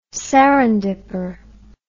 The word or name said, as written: serendipper